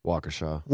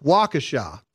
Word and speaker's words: Waukesha: Uh, do you know how to pronounce the town's name? Waukesha. Waukesha. Thank you.